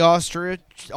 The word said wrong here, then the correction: Ostrov: Do I always say it wrong? – You just have an accent, so it sounds weird. – What is it? – Ostrov. – Ostrov.